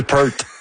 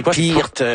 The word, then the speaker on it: Peart: Neil Peart. – C'est même pas ça. – Non, c'est Peart, c'est quelque chose.